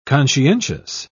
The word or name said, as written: conscientious